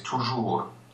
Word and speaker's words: toujours: And we follow the same rule, basically, that says that we should not elide to the S of toujours. Mm-hmm. We should just pronounce the R and go on to the next vowel.